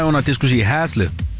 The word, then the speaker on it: Haslev: Og vi skal byde velkommen til dagens deltager, Dorte fra Haslev. Hej med dig, Dorte. – Hej. – Udtalte jeg det rigtigt nok, Haslev? – Nej, Haslev.